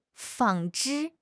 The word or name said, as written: 纺织